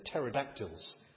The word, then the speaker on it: pterodactyls: And it was only later I discovered it's a silent T. They're pterodactyls.